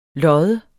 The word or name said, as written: loddes